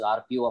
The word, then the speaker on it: ARPU: It's called ARPU, pronounced RPU, of course, we all know in terms of the telecom sector.